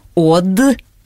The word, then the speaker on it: Од: Откопал он э, бар под названием «Од», «Д», или все таки правильно «Од», ну, как? Ну, читается как «од».